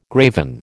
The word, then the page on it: graven: American English